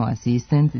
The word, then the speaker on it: asistent: Ispravno je reći samo asistent, dirigent, absolvent, element. Ovako akcentovanje rezultat je pravila da posljednji slog u reči ne može biti naglašen.